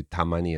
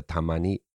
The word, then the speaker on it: tamani: Sorry, just to go back to tamani and tokidoki. What's the intonation, the pronunciation for tamani? – Tamani. – Tamani. Mm-hmm. Okay. And then tokidoki is what? – Tokidoki. – Tokidoki. – So pretty similar.